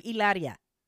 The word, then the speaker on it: Hilaria: Well, it's been an odd week for, so this article says, it's been an odd week for Hilaria. – No, the H is silent. The H is silent. She was calling herself Hilaria.